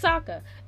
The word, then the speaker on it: Sokka: Next, Sokka. Okay, I don't know why I said it like that. It's Sokka. They call him Sokka in the show.